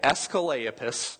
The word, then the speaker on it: Asclepius: Asclepius.